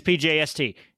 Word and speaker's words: SPJST: SPJST. SPJST. That's how you pronounce it, because no one can pronounce Slovanska, whatever that is.